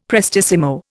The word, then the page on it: prestissimo: Indian